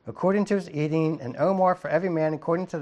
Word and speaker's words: omer: And when they did meet it out with an omer, that's how you pronounce it, he that gathered much had nothing over, and he that gathered little had no lack.